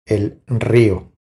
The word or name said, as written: El rio